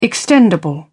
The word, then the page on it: extendable: British English